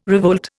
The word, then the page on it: revolt: Indian